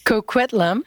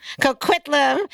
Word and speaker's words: Coquitlam: I am so happy to have someone here from Coquitlam. Did I say that right? – Coquitlam. – Coquitlam in British Columbia.